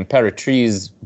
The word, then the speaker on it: Imperatriz: And it's like, those possible heights are interesting because I didn't know she was from, and I'm going to do my best, you know, Portuguese pronunciation, Imperatriz, Brazil, which is like the metro, it's inland, it's on a river.